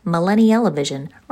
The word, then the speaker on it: Millennial-O-Vision: Hey everybody, jumping in real quick to let you know that since recording our first pancake, we named our podcast Millennial-O-Vision, rhymes with Smell-O-Vision, because we are millennials who were raised by television and really use it to process and digest all of life.